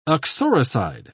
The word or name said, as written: uxoricide